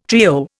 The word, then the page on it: trio: Indian